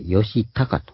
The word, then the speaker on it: 義旗: お酒飲む酒の、それか木の枝の枝に、義、正義の義に旗と書いて、よし、たかと。義の旗と書いて、よし、たかと読むので、普通に読んだらまずこれは、誰でも栄田義旗と呼んでしまうところなので気をつけるということですね。